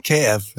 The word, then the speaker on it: Kayef: Diesen K KF, oder wie spricht man den aus? KF, ja.